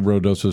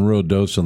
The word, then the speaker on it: Ruidoso: And if you are looking for news on Rodoso, let me give you a website to go to, okay? Rodoso -NM, and I hope I say that like a New Mexican, right?